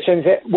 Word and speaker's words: Shenzhen: Okay, and how would you compare Beijing to, and I hope I'm pronouncing it correctly, Shenzhen? – Yeah, Shenzhen.